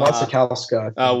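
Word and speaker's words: Wasikowska: It was a Western with Robert Pattinson and Mia Wachowski. Is that how you say her name? From Wachowska.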